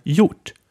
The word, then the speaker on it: gjort: Then we have gjort, which means done. And this word is written with G and J in the beginning, so the G is silent here.